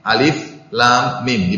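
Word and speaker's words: alif lam mim (الم): Makanya dibacanya bukan alif seperti alif lam mim, tidak dibaca alam, tapi alif lam mim, dibacanya per huruf ya.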